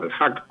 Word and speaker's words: Al-Haq: La ONG Al-Aq, o Al-Haq, como se pronuncia, se define como una organización de derechos humanos.